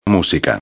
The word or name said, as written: musica